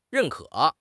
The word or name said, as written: 认可